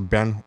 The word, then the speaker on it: Berns: And I was outside this club waiting to get in and it was called Band. It was B-E-R-N-S. It was they pronounce it Band Hotel.